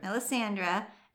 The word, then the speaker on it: Melisandra: So, how do you think this name is pronounced? Mm. – Well, I would have, yeah, it's Millie, yeah, Mila, Melisandra. Okay. Melisandra, and then it's, hold on. You got it.